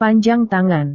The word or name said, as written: panjang tangan